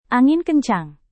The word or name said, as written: angin kencang